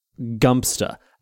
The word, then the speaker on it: Godmanchester: It's the same for Worcestershire, Worcester, apparently, but the worst of them all is definitely Godmanchester, which they claim is actually pronounced Gumpster.